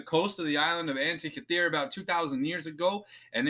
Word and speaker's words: Antikythera: But at some point, a Greek ship sank off the coast of the island of Antikythera. I think I said that right, but if I did not... – Sounds right to me.